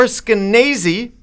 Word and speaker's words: Skenazy: And of course, we're going to have Lenore Skenazy, rhymes with crazy.